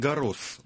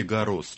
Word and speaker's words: Гаррос: А это Ролан Гаррос, он все-таки Гаррос читается, французы читают имя летчика. Да-да-да, Гаррос все-таки. Да-да, читают S на конце.